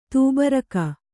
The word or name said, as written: ತುಬ್ಬರಿಕೆ